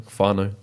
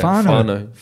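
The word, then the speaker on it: whānau: Fano. – Beautiful. – Is that how you say it? – It's Māori. Yeah. – Fano. – It means family.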